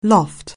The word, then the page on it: loft: anglais